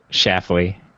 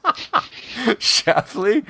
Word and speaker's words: Schlafly: How about the schla schlafly coffee stout? Shafley. – Oh, the L, silent. Shafley.